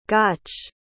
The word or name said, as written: gotch